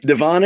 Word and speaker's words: Devon: Well, I guess it's not pronounced Devon, because otherwise you wouldn't have brought it up. – Exactly. – So I'm just going to guess Divine. – Devon it is.